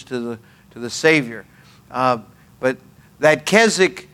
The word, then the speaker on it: Keswick: The old Keswicks, spelled Keswick but pronounced Keswick, the old Keswick preachers, they'd say this.